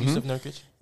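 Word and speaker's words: Nurkić: Nurkic? – Mm-hmm. Yusuf Nurkic.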